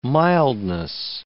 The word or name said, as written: mildness